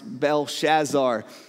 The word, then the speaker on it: Belshazzar: It wasn't just that moment, But he also experiences once King Nebuchadnezzar passes away, and then his descendant Belshazzar, sometimes pronounced Belshazzar, depending on how it comes out of my mouth at the moment,